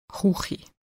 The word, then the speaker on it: Chuchi: Das K wird häufig wie Ch in doch ausgesprochen. Das Wort Küche wird zur Chuchi.